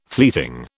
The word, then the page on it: fleeting: British English